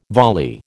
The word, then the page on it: volley: American English